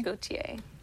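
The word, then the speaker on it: Gotye: Like, it's pronounced Gautier? – Gautier, yes.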